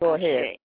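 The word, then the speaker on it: Àṣẹ: In Yoruba, it's spelled A-S with a dot up under it and an E. For the English eye or the English ear, it would be A-S-H-E, Ashe.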